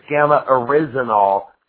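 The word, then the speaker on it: gamma-oryzanol: Okay, I always said gamma-orzinol, so it's gamma-orizinol.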